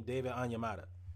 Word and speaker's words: Onyemata: David Onyemata.